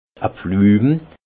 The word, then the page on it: e Pflüm: Haut-Rhin; Munster